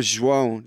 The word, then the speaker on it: João: Well, I mean, like at school, my name got butchered, you know, João. Like it's John in English.